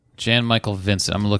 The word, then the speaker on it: Jan: It's Jan, pronounced Jan, like pronounce, jam, it's Jan, but with an N.